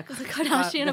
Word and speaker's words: Kardashian: How did I say that? Kardashian? – Yeah. – Kardashian apologist. – Like, it's not Tarjay.